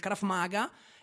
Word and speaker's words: Krav Maga: Sì, il sistema di difesa personale alla quale noi ci approcciamo è proprio il Krav Maga. Diciamo che non è un vero e proprio sport il Krav Maga,